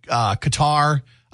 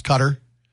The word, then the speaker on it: Qatar: Some people say Qatar. I've heard it say pronounced Cutter. – Oh, I've only heard Qatar. – Cutter is how a lot of the news broadcasters have been saying it.